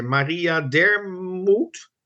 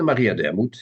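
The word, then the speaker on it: Dermoût: En hoe spreek je dat uit netjes? Maria Dermoet? – Ja, de Maria Dermoet, ja, zeker.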